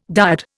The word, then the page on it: dyad: Indian